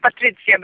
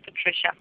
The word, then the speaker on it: Patricia: In Polish, it's pronounced Patricia, but in English, you said it perfectly. It's Patricia.